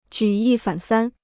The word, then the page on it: 举一反三: jǔ yī fǎn sān